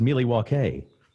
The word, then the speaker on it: Milwaukee: And he goes on this little monologue about, actually, it's pronounced Milwaukee, which is Algonquin for the good land.